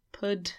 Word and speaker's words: pud: Hard p. Pud. Like spud. Say spud.